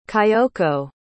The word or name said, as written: kayoko